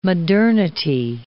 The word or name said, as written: modernity